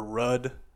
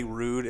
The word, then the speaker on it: Ruud: It's called Rude or Rudd. We say Rude. It's spelled R-U-U-D.